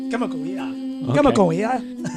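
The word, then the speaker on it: Kamakawiowele (Kamakawiwoʻole): Israel Izzy Kamakawiowele. – Oh, Izzy Kamakawiowele. – Wait. Let's see. Hold on. Say that name. – Jeez. All right, here we go. Hold on. Izzy. – Israel? – Kamakawiowele.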